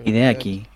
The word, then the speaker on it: Hideaki: Hideakiano, ¿por qué le dije Hideaco? Porque. Hideaki. Hideaki lo pronuncia.